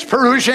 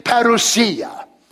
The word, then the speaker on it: Parousia: Parousia, English pronunciation, Greek, parousia.